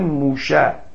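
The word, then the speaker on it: موشه (משה): تو عبری سین رو شین تلفظ میکنن، مثلا موسا رو میگن موشه، موشدایان، شنیدید که موشدایان، یا مثلا به سلام میگن شام، شامه این سلام.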